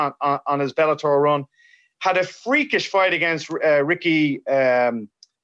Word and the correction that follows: Bandejas: Bandejas. – No, I'm saying that wrong. Bandejas.